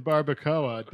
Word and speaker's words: barbacoa: It smelled like beans and cheese and barbacoa.